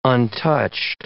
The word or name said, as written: untouched